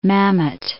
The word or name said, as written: Mamet